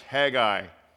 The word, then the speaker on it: Haggai: We're starting a new series this morning, 3 weeks in the Old Testament book of Haggai. It's also pronounced Haggai. Both are acceptable pronunciations, so let's not have any fistfights over that one after church.